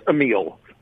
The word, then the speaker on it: Amile: It's AMILE.